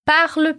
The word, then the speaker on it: parle: A primeira frase é parle plus fort, parle plus fort, que significa fale mais alto. Atenção à pronúncia da palavra parle. O E praticamente some no final.